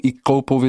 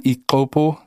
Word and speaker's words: Ixopo: Ixopo wird's geschrieben, Ixopo wird's ausgesprochen mit so einem, mit so einem Klick. Ich kann es nicht so richtig. Ich habe es versucht zu lernen, Ixopo, so ähnlich wird's ausgesprochen.